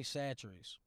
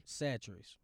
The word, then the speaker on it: Chachere's: Tony Satchery's. – Why is it spelled like that? – Tony? Satchery's. – Satchery's. But why is it spelled C-H-A-C-H-E-R-E-S? What does that even mean? – Satchery's.